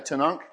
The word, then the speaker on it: Tanakh: They call it the Tanakh, I think, right? Tanakh is how you say it.